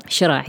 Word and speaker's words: Sheraa: Sheraa, yes.